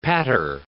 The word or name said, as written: patter